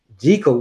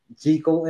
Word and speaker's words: Jekyll: Not meaning I'm Jekyll and Jekyll, which is the correct pronunciation. Let me say this, not Jekyll, it's actually the original author's name, Jekyll and Hyde.